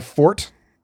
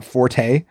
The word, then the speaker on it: forte: It's my fort. Okay. So you don't say the E. It's not forte. If you're discussing someone's fort, as in a strength, the E is silent. Forte is correct only if you're using it as a musical term.